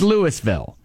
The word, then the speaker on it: Louisville: The town in Northeast Ohio that's right outside of Canton in the Football Hall of Fame is spelled the same way as Louisville, but it is pronounced Louisville.